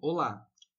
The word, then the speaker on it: hola: É, por exemplo, olá. Na verdade, o olá se escreve H-O-L-A. Só que o H não se pronuncia, né?